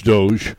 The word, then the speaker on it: DOGE: The Secretary of Transportation said earlier this month that the Doge people would how are we going to pronounce it? Doge or Dodge? – Doge? It's Doge. Everybody's saying Doge, yeah.